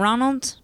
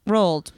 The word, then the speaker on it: Roald: It's not Ronald? – Roald. – Why did I think it was Ronald? – Because it's spelled like Ronald with the N taken out.